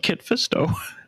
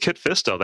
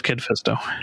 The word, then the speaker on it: Kit Fisto: I was going to joke about that, but go with Kit Fisto. Then you can say you got fisted when he actually does something. – It's true. And I never pronounce Kit right. It's always Kid. It's always Kid Fisto.